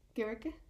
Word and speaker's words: Gericke: Which is Inga Gericke? Gericke? It's German, so you have to pronounce the E.